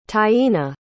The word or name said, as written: taina